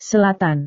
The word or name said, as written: selatan